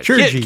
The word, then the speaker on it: Tschirgi: How would you pronounce this? T-S-C-H-I-R-G-I. – Look it up. – Chirgi. – Yeah, Chirgi.